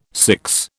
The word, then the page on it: six: British English